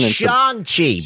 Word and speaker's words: Shang-Chi: It's Shang-Chi, by the way.